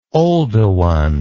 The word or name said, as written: Oldowan